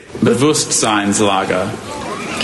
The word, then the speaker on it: Bewusstseinslage: Bewusstseinslage.